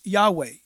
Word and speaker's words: Yahweh: that he is Yahweh.